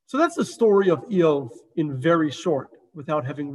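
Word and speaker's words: Iyov: So the Hebrew name is Eov. That's his Hebrew name.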